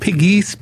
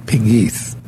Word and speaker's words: Pegues: It's really Pegue. That's the way they pronounce it. Pegues. – We call it Pegues. P-E-G-U-E-S.